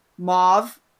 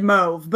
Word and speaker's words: mauve: But, yeah, the maroon jacket memo, and then Nigel was like, tried to, like, it's mauve. He said mauve, but it's pronounced mauve.